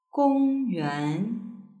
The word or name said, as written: gongyuan